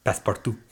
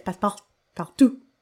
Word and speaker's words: Passepartout: Yes, passepartout. – That's not even how you say it. It's passepartout.